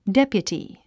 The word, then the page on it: deputy: dépjuti; デピュティ